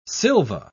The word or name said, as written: Sylva